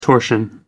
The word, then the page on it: torsion: American English